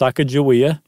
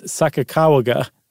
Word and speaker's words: Sacagawea: We have such things as historians and people who listen to Native Americans who have been told over the years, no, it's not Sacagawea, it's Sacagawea, right? There's one pronunciation of it. But that it's not ja, it's ga.